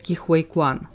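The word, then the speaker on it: Ke Huy Quan: Следующая номинация – актер второго плана, и статуэтку забирает Ки Хуэй Куан. Это, кстати, правильное произношение его имени, я нашла несколько интервью, где он сам себя так называет.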